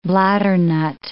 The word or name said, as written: bladdernut